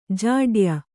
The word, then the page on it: ಜಾಡ್ಯ: Kannada; jāḍya